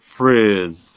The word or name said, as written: friz